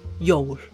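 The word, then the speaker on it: Jól: 这字跟上一次那个巨人比起来了，简单多了，大家不用担心，很容易学的，只是三个字母，我就就是这样的，J O 上面加一点 L，它的发音是 Yo，超简单的吧？可是当然你就不会只是说圣诞节，你会说圣诞节快乐，那圣诞节快乐是怎么讲呢？它的念法就是 gladly you。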